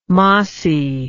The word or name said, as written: mossi